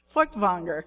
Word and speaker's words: Feuchtwanger: There was a world-famous German-Jewish author of historical fiction named Leon Feuchtwanger. I have practiced pronouncing that many times. Feuchtwanger.